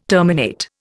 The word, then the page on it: terminate: Indian